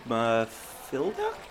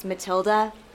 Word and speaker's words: Matilda: Mathilda? – You had me until that. – It's Matilda. H is silent.